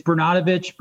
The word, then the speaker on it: Bernatovicz: Yeah, it's Bernatovicz, but it's been mispronounced every single time anybody's given it a first crack, which you got really close.